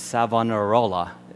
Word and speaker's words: Savonarola: the person who comes to the center of that reform movement and the new leader is an Italian man named Savonarola. Is that right, Eric? Is that right as well, that Italian pronunciation? Okay. Do I need to do this with my hands as I say Italian? Okay, very good.